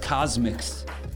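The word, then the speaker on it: Cosmc's: It's spelled C-O-S-M-C apostrophe S, Cosmix.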